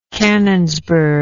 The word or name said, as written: canonsburg